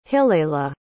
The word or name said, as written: hillela